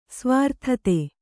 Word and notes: ಸುವೃತ್ತತೆ: suvřttate

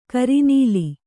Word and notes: ಕರಿನೀಲಿ: kari nīli; Kannada

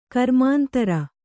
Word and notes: ಗ್ರಾಮಾಂತರ: grāmāntara

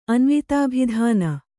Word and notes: ಅನ್ವಿತಾಭಿಧಾನ: Kannada

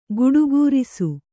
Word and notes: ಗಟುಕರಿಸು: kannada

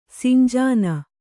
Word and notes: ಸಿಂಜಿನಿ: sinjini

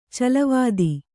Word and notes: ಚಲ್ಲವತ್ತ: callavatta

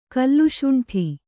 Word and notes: ಕಲ್ಲುಸುಂಟಿ: kallusuṇṭi

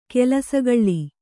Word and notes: ಕಲಸುಗೂಳು: kalasugūḷu

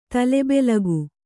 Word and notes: ತಲೆಬೆಲಗು: tale belagu

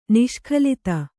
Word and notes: ನಿಷ್ಕಲಿತ: niṣkalita

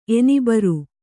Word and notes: ಎನಿಬರ್: Kannada

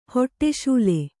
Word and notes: ಹಡಸಲೆ: haḍasale

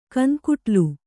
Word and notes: ಕನ್‍ಕುಟ್ಲು: kan kuṭlu

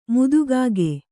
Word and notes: ಮುದಿಕಾಗೆ: mudi kāge

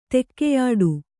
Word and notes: ತೆಕ್ಕೆಯಾಡು: kannada

